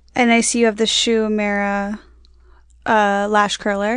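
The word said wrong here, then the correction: Shu Uemura: Did I say it? Can someone say it right? – Shoe Yomora. – Oh, thank you.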